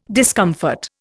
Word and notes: discomfort: Indian

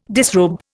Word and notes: disrobe: Indian

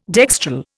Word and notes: dextral: Indian

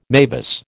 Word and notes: mavis: American English

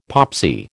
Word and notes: popsy: American English

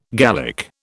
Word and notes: Gallic: British English

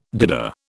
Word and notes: doodah: British English

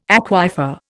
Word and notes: aquifer: Indian

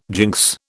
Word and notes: jinx: British English

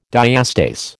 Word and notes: diastase: American English

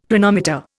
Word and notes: Planometer: Indian

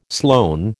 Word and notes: Sloane: American English

